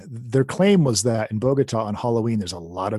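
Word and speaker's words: Bogotá: Well, there's probably lots of really interesting things, but the final one that I have here, last gig in Bogota. How do you say that? – Bogota was canceled because guerrillas bombed his hotel weeks before his arrival.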